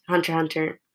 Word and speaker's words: Hunter x Hunter: Today we're going to be talking about Hunter x Hunter, which is without the X, Hunter Hunter. They don't pronounce the X, which is weird.